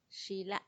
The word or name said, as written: xila